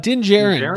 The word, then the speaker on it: Din Djarin: Din Djarin? Din Djarin? – Yeah. Djarin? – Din Djarin, the Mandalorian.